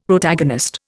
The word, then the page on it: Protagonist: Indian